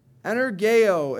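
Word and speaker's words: Energeo (ἐνεργέω): That word used for effectual, fervent, that Greek word, the first five letters of it, E-N-E-R-G, next letter is E, then O. That sound like a word? Energy, right? Energeo is how it's pronounced in the Greek, but it's an operative, or it's operative, put forth power, be at work.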